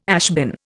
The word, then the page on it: ashbin: Indian